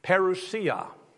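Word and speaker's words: Parousia (παρουσία): And that's why this message is entitled today, Parousia is how it's pronounced. Parousia, it's a Greek word, but the one thing I found fascinating, it's a noun.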